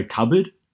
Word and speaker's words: cupboard: So cupboard, people sometimes pronounce the P. – Cupboard, but like that's just dumb. Colonel, because it's spelled C-O-L-N-E-L.